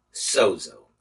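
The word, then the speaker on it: sozo (σώζω): This word saved comes from the Greek root word sozo, S-O-Z-O, and it's pronounced sozo.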